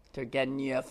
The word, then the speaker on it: Turgenev: So there's some of you sitting around reading Turgenev. – That was well pronounced. You had the yeah Turgenev.